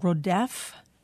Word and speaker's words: Rodef: You write about something called Rodef. I don't know if I'm pronouncing that correctly. – That's right. – Moser or M-O-S-E-R? – Moser.